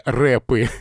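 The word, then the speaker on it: wrap: Это рэп, но не в смысле музыкальный стиль, а в смысле как бы в рэп, только «в» не произносится, да, как, опять же, еще одна калька с английского, что-то завернутое.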